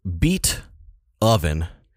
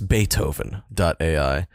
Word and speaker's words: Beatoven: It's called Beat Oven. B-E-A-T. Oven like an oven. – Yeah. – Beatoven.ai. Or maybe it's Beethoven.ai. But I call it Beat Oven. – I mean, it's spelled like Beat Ovens, but I think it's a play on Beethoven.